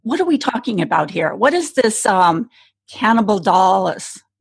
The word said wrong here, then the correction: cannabidiol: Cannibal doll. I'm not saying that right. CBD or you say it. – CBD. Yeah. Cannabidiol, hemp oil.